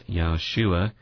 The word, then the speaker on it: Yahshua: But it takes a man named Joshua, pronounced Yahshua in the Hebrew.